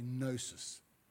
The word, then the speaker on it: gnosis: It's got a G in front. You don't pronounce the G because the Greek scholars say, you just say gnosis.